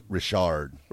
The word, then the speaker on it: Richard: I believe that's Richard. – Richard? – Yes. I'm quite a hockey guy. – Henry Richard. So it's pronounced Richard instead of Richard.